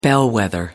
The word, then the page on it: bellwether: American English